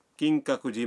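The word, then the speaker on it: کنگ کاکو جی (金閣寺): مثلاً آپ کیوتو کے کنگ کاکو جی مندر جانا چاہتے ہیں. مندر کے نام کا تلفظ ہے کنگ کاکو جی.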